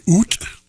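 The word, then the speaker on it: août: It is the last Sunday in August, or as the French say, oot. That's how they pronounce it, I'm told, anyway.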